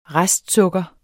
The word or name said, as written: restsukker